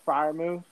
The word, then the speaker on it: Freiermuth: Pat Freiermuth, is that how you pronounce his last name? – Yeah.